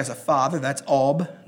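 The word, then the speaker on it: Ab: Last of all, number 10 is Ob, meaning Father. It's spelled simply A-B. Ob.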